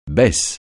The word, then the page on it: baisse: francais